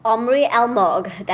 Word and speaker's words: Omri Almog: Tell us a little bit about how you got today's interview with Omri Almog. Is that how it's pronounced? – Omri Almog, that's right.